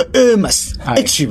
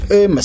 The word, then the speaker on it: Hermes: 应该 Hermes，H 唔发音嘅 Hermes 手袋 500万一个，就可能就特登做一个俾英女王，系嗰啲嘢嚟嘅。